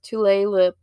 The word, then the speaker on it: Tulalip: The way it looks and the way you're supposed to pronounce it, according to what I found, are two different things. So the Tulalip area.